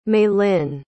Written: malyn

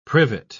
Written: privet

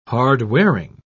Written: hard-wearing

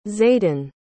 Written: zaiden

